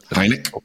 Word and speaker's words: Hynek: What about Dr. J. Allen Hynem? Hynem? What? – Hynek? – What? Okay. Sorry. I mispronounced that.